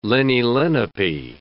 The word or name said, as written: Lenni-Lenape